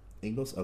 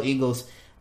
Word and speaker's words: Ingles: It wasn't all Donovan Mitchell, but it was Donovan Mitchell, Ingles. Okay, I said it right. Joe Ingles.